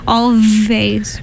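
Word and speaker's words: Alvvays: That first song was Archie Marry Me by a band called Always, spelled with two V's instead of a W. Always.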